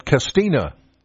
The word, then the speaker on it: Castanea: Castanea, but there are locals up there. They pronounce it Castina.